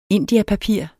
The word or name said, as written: indiapapir